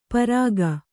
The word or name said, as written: ಪಾರಕ